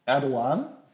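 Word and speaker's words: Erdoğan: Ja, aber gut, finde ich auch, da hat sie vielleicht falsch äh, gerechnet mit Prost, mit dem Herrn Erdogan. Man darf ja das G nicht aussprechen, habe ich gelernt.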